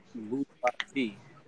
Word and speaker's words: bourgeois: It's actually pronounced bourgeoisie, bourgeois or bourgeoisie. Both of them have been used, and it's French.